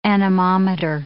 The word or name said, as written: anemometer